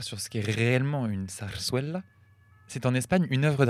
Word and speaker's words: zarzuela: Alors, je tiens à dire d'abord que nous avons toutes mal prononcé parce que les deux Z se prononcent S et le U se prononce Oué. Donc on dirait plutôt zarzuela.